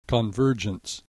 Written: convergence

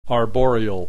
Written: arboreal

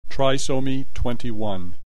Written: trisomy 21

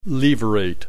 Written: levirate

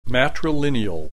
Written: matrilineal

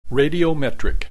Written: radiometric

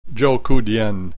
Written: Zhoukoudian

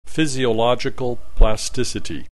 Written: physiological plasticity

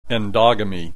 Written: endogamy

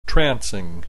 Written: trancing

